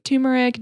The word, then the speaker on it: turmeric: So you can add like a bunch of different stuff, and it has like turmeric, ginger, cinnamon. Excuse me, what? – There's an R after the U. Look it up. – Turmeric is how you pronounce it.